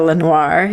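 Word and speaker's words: LeNoire: The cast features alumni students from the Rosetta Lenore. You are good with languages. How would I pronounce that word? – Where is it? Rosetta Lenoir, yeah. – Is that how you say that? – Yeah.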